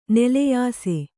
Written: ನಲಿಯಿಸು

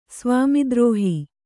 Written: ಸ್ವಾಮಿದ್ರೋಹ

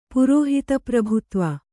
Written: ಪುರೋಹಿತಪ್ರಭುತ್ವ

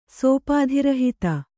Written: ಸೋಪಾಧಿರಹಿತ

